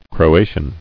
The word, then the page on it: Croatian: Cro·a·tian